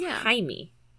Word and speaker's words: Jaime: And it's Jamie, J-A-M-I-E. – Oh, but Jaime, but with the pronunciation. – Because of the Hispanic pronunciation, it's Jaime.